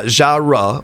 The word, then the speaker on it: genre: It's pronounced with two syllables. Genre or genre.